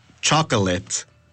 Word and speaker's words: chocolate: Igual, se escribe C-H-O-C-O-L-A-T-E. – ¡Oh! Pero se pronuncia de diferente manera, ¿verdad? – Ajá. Se pronuncia chocolate. Chocolate. – ¡Oh! Chocolate. I want a chocolate.